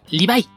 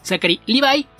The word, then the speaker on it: Zachary Levi: existe también en América Latina, es Sean Levy, y por ejemplo, uno que es parecido y se pronuncia muy distinto, el protagonista de Shazam es Zachary Levi, se escribe Levy con i latina, pero ahí se pronuncia Levi. Piensen en la marca de jeans, y si se dan cuenta se escribe muy similar, así es como se pronuncia entonces Zachary Levi y Sean Levy, por poner un ejemplo de una diferencia con estos nombres.